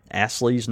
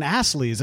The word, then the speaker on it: Astley's: And do you pronounce the T? – I'm trying to. – Okay. Well, I was just curious because it sounded like you were saying Astley's, and I was like, Astley's. – Yeah. – I was like, there's Astley's. I know Astley's, but Astley. Astley. You know, it's funny because it sounds the same way when you pronounce the T or double.